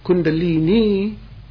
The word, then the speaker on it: Kundalini: Kundalini, and the accent is on the last syllable, Kundalini is the goddess.